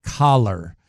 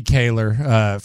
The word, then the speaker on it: Kahler: They are directing, this is per The Athletic, Kalen Collar of The Athletic. Could be Kaler, forgive me, K-A-H-L-E-R. Forgive me for mispronouncing the name.